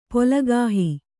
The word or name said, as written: ಪೊಲಗಾಹಿ